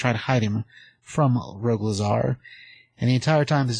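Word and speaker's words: Rogol Zaar: But just so people know, this is Rogue Lazar. Rogue Ol Zar. Let me spell it for you. It's R-O-G-O-L, then Z-A-A-R. That's two A's in there. Rogue Lazar.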